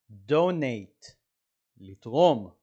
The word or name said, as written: donate